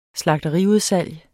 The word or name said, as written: slagteriudsalg